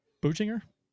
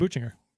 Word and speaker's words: Buchinger: Michael, I pronounce this, Buchinger? I'm going with Buchinger.